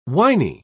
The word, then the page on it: winy: British English